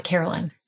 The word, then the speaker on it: Caroline: And in the South, that people pronounce it Carolyn. So when we moved down to the South, everyone pronounces Caroline, even though you can see the word line is Carolyn.